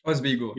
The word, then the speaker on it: Oswego: I don't know if I'm pronouncing wrong, but is it Oswego? – Oswego. – Yeah.